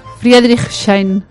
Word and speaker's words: Friedrichshain: Friedrichshain.